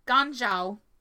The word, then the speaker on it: Ganzhou: Gonzao sounds like Gonzo. uh Ganzhou Gan, that's how you say it.